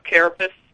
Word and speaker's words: carapace: The carapace, right? That's how you say it.